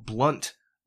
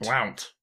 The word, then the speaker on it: Blount: We all down in this area where I was raised at say blunt. It, that's that's how we say it, but it's spelled B L O U N T. Blount. Blount. It's not Blount. It's Blunt.